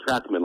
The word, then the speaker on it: Trockman: Trockman, like a track runner.